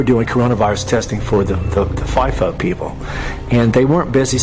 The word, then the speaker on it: FIFO: I call it FIFO, but some people might pronounce it FIFO, F-I-F-O.